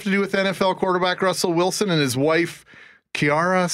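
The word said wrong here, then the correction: Ciara: Sierra. – How do you say it? Let's just ask her. – Sierra. Uh-huh. – So it's actually Sierra. Uh-huh. You have to say it like that.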